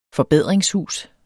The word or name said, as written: forbedringshus